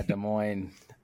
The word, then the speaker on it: Des Moines: After a year of college at BYU, he went on an LDS mission to Iowa, Des Moines. Am I saying that right? There's always like some, people from St. Louis, they're like, that's not how you say it. – Yeah, Des Moines.